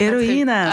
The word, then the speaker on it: Héroïnas: On a choisi Heroinonas. – Très bien. – Je crois que je le prononce Héroïnas. – Pas très... Ah, voilà. De votre prochain album.